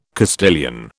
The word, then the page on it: Castilian: British English